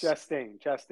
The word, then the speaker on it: Chastain: Chastain.